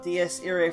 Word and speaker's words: Dies Irae: So Matt, of Define Prog fame, can you also spell out Dies Irae for dumb people like me who don't know Latin? – Yeah, it's spelled like dies, D-I-E-S, and then I-R-A-E.